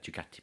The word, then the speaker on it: Ducati: Does it rhyme with Muffati? – Muffati. Yes. Yeah. I'd love. I would absolutely love more than anything in the world a Ducati Panigale V4R.